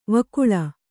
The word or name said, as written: ವಕೀಲಿ